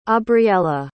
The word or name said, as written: aubriella